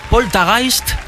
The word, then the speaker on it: Poltergeist: Es una palabra alemana que realmente, bueno, se pronuncia Poltergeist.